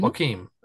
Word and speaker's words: Joaquin: Joaquin. Mm-hmm. Joaquin, so I did not know that.